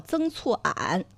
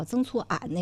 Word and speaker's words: 曾厝垵: 它这个叫曾厝垵或者曾厝垵，有些人当地的因为发音呢它不太一致哈。我看这个如果按照书面来讲，当地应该叫曾厝垵那个地方。